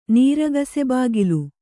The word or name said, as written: ನೀರಗಸೆಬಾಗಿಲು